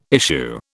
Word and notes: issue: British English

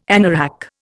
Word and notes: anorak: Indian

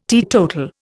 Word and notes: teetotal: Indian

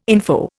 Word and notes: info: Indian

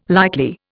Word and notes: likely: Indian

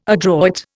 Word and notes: adroit: Indian

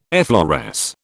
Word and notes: effloresce: British English

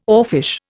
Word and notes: oarfish: Indian

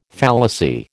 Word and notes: fallacy: American English

